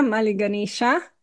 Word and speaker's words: Mali Ganeša: A sem prav izgovorila Mali Ganeša? – Ja, Mali Ganeša, ja.